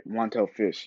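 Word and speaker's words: Montell Fish: I can't think right now, but no, I think I said his name right. Let me check again. Let me check. Yeah, I said it right. Montel Fish.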